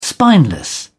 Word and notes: spineless: British English

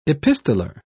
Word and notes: epistoler: British English

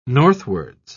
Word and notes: northwards: British English

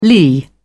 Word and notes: lee: British English

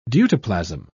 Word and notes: deutoplasm: British English

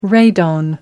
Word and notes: radon: American English

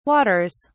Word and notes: waters: British English

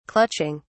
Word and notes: clutching: British English

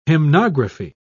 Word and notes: hymnography: British English

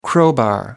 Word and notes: crowbar: American English

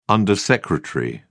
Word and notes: undersecretary: British English

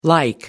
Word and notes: the like: American English

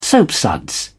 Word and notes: soapsuds: English; British English

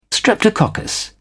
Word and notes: streptococcus: British English

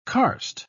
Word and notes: karst: British English

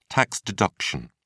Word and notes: tax deduction: British English

